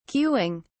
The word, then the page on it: queuing: British English